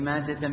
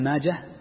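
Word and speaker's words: ماجه: نعم، هذا حديث ابن ماجة، وذكرت لكم قبل أن ابن ماجة المحدثين ينطقونه بالحاء الساكنة مطلقا، حال الوصل وحال الوصل، حال الوصل وحال الوقف، ولا ينطق بالتاء.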